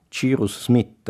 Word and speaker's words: Cyrus Smith: Cyrus Smith, lo pronuncio così perché allora lo pronunciavo così, invece è un civilissimo e luminoso eroe dell'ingegno.